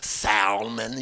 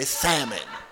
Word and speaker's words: salmon: You don't, that's just like salmon. You don't say salmon, you say salmon.